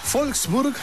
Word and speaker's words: Wolfsburg: Saludamos a Siegfried desde Wolfsburg, Alemania. No, es Wolfsburg, porque la W se pronuncia como V corta, ¿no? O V, ¿no? Se dice V.